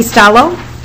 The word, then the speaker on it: Staloch: My name is Heidi Stallo. – And how do you spell that last name? – S-T-A-L-O-C-H.